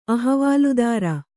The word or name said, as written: ಅಹವಾಲುದಾರ